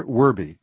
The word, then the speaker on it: Werbe: which is Peter Werby. My last name is spelled W-E-R-B-E.